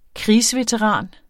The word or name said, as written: krigsveteran